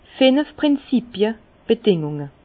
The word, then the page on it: finf Prinzipie, Bedingunge: Bas-Rhin; Herrlisheim